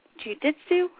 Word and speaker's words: Jiu-Jitsu: At 13, she won the Jiu-Jitsu.